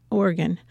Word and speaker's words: Oregon: He's a two-time winner of both the Pacific Northwest Booksellers Association Award and the Oregon. Did I say that right? – Mm-hmm. – Okay, yeah.